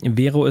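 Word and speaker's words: Wero: Vero ist ein Anbieter, der von europäischen Banken ins Leben gerufen wurde. Das ist eigentlich eine englische Abkürzung. We für wir und Ro steht für Euro. Wird aber deutsch ausgesprochen.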